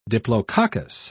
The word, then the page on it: diplococcus: British English